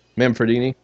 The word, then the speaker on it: Manfredini: How do you pronounce the guy that composed this? What's his name? Is it Manfredi? – Harry Manfredini. Yep.